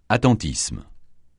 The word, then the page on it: attentisme: francais